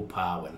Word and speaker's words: Pawan: But for our mob from Briwarana down, we call it Bawan. – Bawan. – Yeah, with a P. More Pawan.